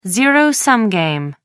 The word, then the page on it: zero-sum game: American English